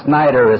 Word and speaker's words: Snyder: As you heard, my name is Clarence Snyder, and I come from St. Petersburg. For the benefit of anyone here who belongs to the press, the radio, or the films, that name Snyder is spelled S-N-Y-D-E-R.